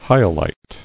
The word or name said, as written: Hyalite